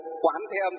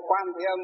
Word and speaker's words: Quán Thế Âm: Ví dụ Nam Mô Quán Thế Âm Bồ Tát hay Nam Mô Quan Thế Âm Bồ Tát đều được cả. Nhưng mà nếu mà đọc đúng thì phải đọc là quán thì đúng hơn.